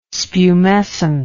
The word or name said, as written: spumescent